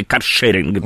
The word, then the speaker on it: каршеринг: Вот знаете, есть прокатные велосипеды и есть каршеринг, да, или каршеринг. Да, если делать ударение правильно, вот, ну вот я брезглив в этом отношении, но я о другом.